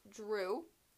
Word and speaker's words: Jrue: Okay, my number 3 is Drew. Spelled like Drew. – Drew Holiday. – So J-R-U-E.